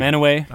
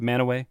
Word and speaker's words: Mantua: Manaway. – I don't know. – Spelled Mantua. – What? – Spelled Mantua, pronounced Manaway.